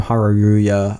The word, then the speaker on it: Haruya: There was no longer Team Channel Fireball, Team Haruya. I just butchered that. Excuse me. – No, that's actually correct. You said it right.